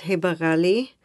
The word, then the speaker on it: Heba Ghali: Yes, but actually we've got the rain sound and we've got the kha sound and we've got the dad. So we've got a little bit of difficult pronunciations that would be hard for others to pick. So you would say Ghali with a J.